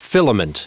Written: filament

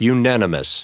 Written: unanimous

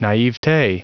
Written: naivete

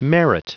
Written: merit

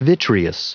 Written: vitreous

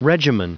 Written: regimen

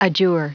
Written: adjure

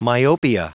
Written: myopia

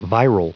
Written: viral

